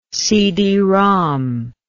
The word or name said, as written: CD-ROM